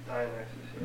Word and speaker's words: Dionysus: Dionysus.